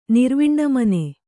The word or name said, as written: ನಿರ್ವಿಣ್ಣಮನೆ